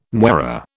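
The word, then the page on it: moiré: British English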